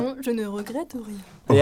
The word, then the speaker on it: Non, je ne regrette rien: Yeah, Edith Piaf, non, je ne regrette rien.